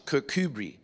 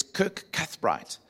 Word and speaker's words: Kirkcudbright: So the spot where they are is right outside the town, and it's pronounced in Scottish Kirkcudbright. But if you say it like phonetically, it's Kirkcudbright. In other words, the church of Cuthbert.